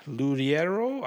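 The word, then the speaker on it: Loureiro: And I do think that Kiko Luriero, I don't know how to say his name. – I think you got it right. Yeah, I think that was it.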